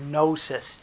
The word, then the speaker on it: gnosis: Gnosticism is an ancient heresy that comes from the Greek word gnosis. Hear the silent G. G-N-O-S-I-S.